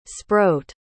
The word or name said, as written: sproat